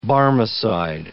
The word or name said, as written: Barmecide